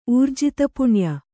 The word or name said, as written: ಊರ್ಜಿತಪುಣ್ಯೆ